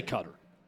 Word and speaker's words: Qatar: No, we can say Cutter. Everyone on the broadcast for Fox said Cutter. They all said Cutter. – When I say a country's name, I know how to say it. – I just love the confidence no, the English pronunciation is Qatar.